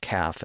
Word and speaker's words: calf: So again, Holofernes thinks all of the letters in a word should be pronounced, even the silent L in calf and half. Again, the L in those words reflects a much older pronunciation, where the L was once voiced, but the L was no longer pronounced in those words in early modern English.